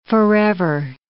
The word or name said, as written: forever